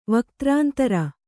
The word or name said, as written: ವಕ್ತ್ರಾಂತರ